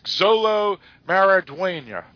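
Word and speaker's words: Xolo Maridueña: In Exlo. – Actually, that's Xolo Maraduena.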